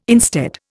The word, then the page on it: instead: Indian